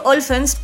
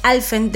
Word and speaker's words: Alfen: Sí, tiene razón, es que recién habíamos dicho Olfens, pero no, es Alfen de chilensis, porque es como, viene de la lengua mapuche, ¿cierto? Algo así. Por ahí va la cosa, claro. Entonces, tal como se escribe, tal como se pronuncia.